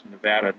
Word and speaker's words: Nevada: One of the drawbacks in Nevada, and that's how you say it, by the way, is Nevada, not Nevada, because people here in Nevada get crazy.